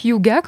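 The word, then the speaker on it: hygge: au moins maintenant on sait qu'il faut prononcer hyuga comme on vient de le voir.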